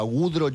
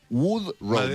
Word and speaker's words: Woodrow: No, Woodrow es un nombre... No sé si sabes, debe ser anglosajón. Que es como si era wood de madera, siempre él decía. Yo me llamo wood, madera, row, remo. Wood, row, W-O-D-R-O-W.